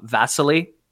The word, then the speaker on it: Vasily: I would almost say it might be easier to say, but I think you're saying it wrong. I think it's Vasily. – Yeah. – Vasily. – Okay, sure. That's the Eastern or Russian name that's in real life. Vasily. – V-A-S-I-L-Y? – Vasily, yep.